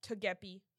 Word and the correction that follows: Togepi: Togepi. Like the Togepi. Pokemon.